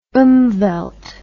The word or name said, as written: umwelt